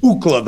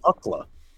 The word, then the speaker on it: Ookla: With Ookla the Mock. – Which was named after UCLA.